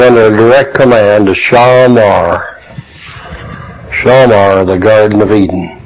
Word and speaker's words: Shamar: Shamar is S-H-A-M-A-R, pronounced Shamar, actually, in the Hebrew.